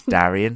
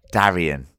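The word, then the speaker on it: Daeron: Darren. It's Darian. – Darren. – Darian. Okay, Darian is better. The way I should pronounce it.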